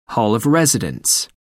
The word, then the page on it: hall of residence: American English